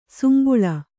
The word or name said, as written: ಸುಂಬುಳ